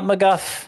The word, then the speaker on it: McGough: Well, it's a fellow Scott. Scott McGuff. – Yeah! – M-C-G-O-U-G-H is how you spell that.